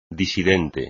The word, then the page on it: disidente: espagnol